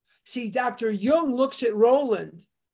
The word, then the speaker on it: Jung: J-U-N-G is Jung, pronounced Jung, not Jung or Jung, but Jung.